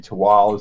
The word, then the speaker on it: Tehuatl: It is spelled T-E-H-U-A-T-L. So it's naturally, most people prize Tehuatl. It's really Tual is the way you pronounce it.